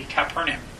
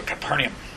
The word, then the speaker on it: Capernaum: That kind of thing would be Capernaum or Capernaum. But I, like I said, I like to pronounce all my vowels if I can, so Capernaum. – Capernaum, that's how I've heard some people. – I have heard that as well. I kind of rush the U.